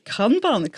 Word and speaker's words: Kanban: Und das eine, weiss ich nicht, ob ich es richtig ausspreche, Kanban. Ist das richtig gewesen? – Kanban, korrekt.